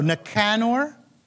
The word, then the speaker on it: Nicanor: No, Nicanor.